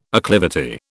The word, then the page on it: acclivity: British English